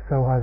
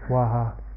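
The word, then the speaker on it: Swaha (स्वाहा): Soha is a Tibetan pronunciation. So somebody says Soha or Swaha. It's the same word exactly.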